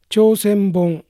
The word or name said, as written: Chosenbon